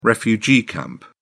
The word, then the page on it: refugee camp: British English